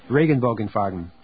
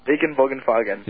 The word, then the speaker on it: Regenbogenfarben: How do you pronounce that German phrase at the beginning of each show? My synthesis in the Regenbogenfarben or whatever. – Regenbogenfarben. – Regenbogenfarben. – Yeah.